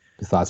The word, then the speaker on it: Bisaccia: So out of the coach, so the Raiders have an interim coach, Rich Bisaccia, I think is how you pronounce his last name. – Bisaccia, so, I think.